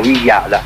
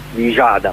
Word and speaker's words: Villada: Facciamo questo sforzo, che io non so se ci riesco, proverò appunto per evitare confusione col toponimo del parco romano, proverò a chiamarlo con un accento spagnolo, Villada, Camila Sosa Villada, o se dovessimo dirlo all'argentina, Villada, ma insomma adesso queste sono sofisticherie.